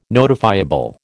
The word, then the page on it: notifiable: American English